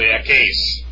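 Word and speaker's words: ekais (ἔχεις): On page 12 in the gray box in the middle, we have first, second, and third person singular, which would be echo, ekais, or actually ekais. Epsilon iota is pronounced as an A, as an eight.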